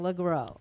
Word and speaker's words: LeGros: And James LeGros, I think I read that the S is silent in his last name. That's why I'm saying LeGros.